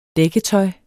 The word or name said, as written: dækketøj